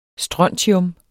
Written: strontium

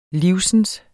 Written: live

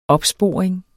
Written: OPSPORING

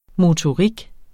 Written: motorik

